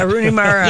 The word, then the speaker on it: Rooney Mara: Did I say that wrong? Mooney Rara. – Yeah, I knew what you were talking about. – Yeah, Rooney Mara.